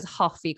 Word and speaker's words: Hoffi: Of course, for your for for your listeners, uh, the best saying with coffee in Wales is "hoffi coffee." – coffee I coffee. like coffee. Yeah. The fact that it rhymes is just brilliant, isn't it?